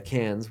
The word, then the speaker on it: Cairns: We also went to Cairns, which is a different part. – Look at you saying it correctly. – Well, because you've corrected me enough.